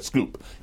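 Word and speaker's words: Schoep: You had a conversation from an accidental courtesy with a guy named, I'm not sure how you pronounce it, Jeff... – Shoop. – Shoop? – I'm sorry, Scoop. He pronounced it Scoop.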